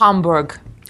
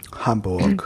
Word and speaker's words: Hamburg: And I believe it's pronounced Hamburg. – Hamburg. Exactly. And also, I know how to pronounce Georg's name now correctly, but I don't know if I can do it.